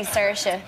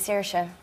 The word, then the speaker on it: Saoirse: I say Saoirse, but a lot of people in Ireland say no, it's actually Saoirse. I think you're saying that wrong.